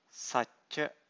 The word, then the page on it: sacca: Pali